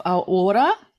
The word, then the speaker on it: AuOra: So we're really excited to hear from you today and especially your general manager of AuOra. Have I pronounced that correctly or not? Please correct yeah. me. – No, you have, um, and just probably a couple of corrections.